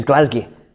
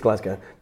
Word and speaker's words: Glasgow: Is that the correct pronunciation, by the way? – It's Glasgow. Yeah. – What? – Glasgow. No, they're going to hate me. It's Glasgow. Don't ever say that to a Scottish person.